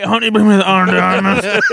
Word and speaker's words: Ana de Armas: With Ana de Armas. – Oh, okay. – And Chris Evans. – You're not doing it right, by the way. – How do you say it? – Ana de Armas.